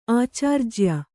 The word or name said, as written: ಆಚಾರ್ಜ್ಯ